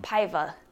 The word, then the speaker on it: Paiva: Paiva.